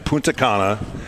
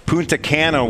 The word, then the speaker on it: Punta Cana: We're live in Punta Cana, which, by the way, is the Spanish pronunciation. Punta Cana would be the English pronunciation.